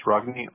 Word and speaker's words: Rogne: It's Rogne, R-O-G-N-E. – Got it. Rogne, R-O-G-N-E. First name, you spell that J-O-S-H, right? – Yes, sir.